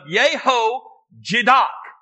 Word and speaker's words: Jehozadak: the son of Jehozadak,